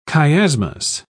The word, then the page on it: chiasmus: British English